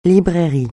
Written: librairie

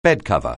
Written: bedcover

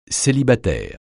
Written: célibataire

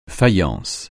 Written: faïence

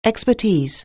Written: expertise

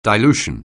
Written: dilution

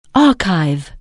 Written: archive

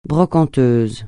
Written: brocanteuse

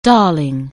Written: darling

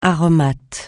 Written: aromate

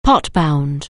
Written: pot-bound

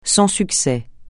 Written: sans succès

